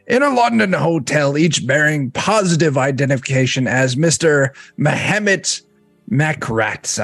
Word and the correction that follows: Mehmet Makryat: I am apologizing for my pronunciation Mehmet of Islington. Makryat. – Yeah, Mehmet Makryat. There's no extra.